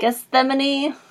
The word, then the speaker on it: Gethsemane: Gethsemane.